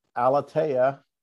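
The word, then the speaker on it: Aleteia: His writing has been featured in several Catholic online publications such as Word on Fire, Voyage Comics, Alteia, did I say that right? Alateia, and Busted Halo.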